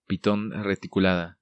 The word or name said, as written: Piton Reticulada